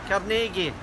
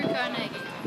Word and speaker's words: Carnegie: As for the differing pronunciations of his last name, well, let the people of his hometown in Scotland have the final word. – Carnegie. – Andrew Carnegie.